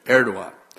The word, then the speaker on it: Erdogan: It says, in the case of Turkey, and I pronounced this wrong and I was corrected between the first and second service, how to say it right. In the case of Turkey's president, Erdogan, that's it, Erdogan, has thrown himself on the side of Russia as Turkey relations with both Israel and the United States have soured.